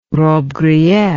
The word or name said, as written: robbe grillet